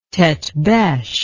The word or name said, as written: tete-beche